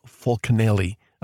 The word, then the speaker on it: Fulcanelli: Fulcanelli. Am I saying that right? Yep.